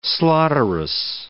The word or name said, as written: slaughterous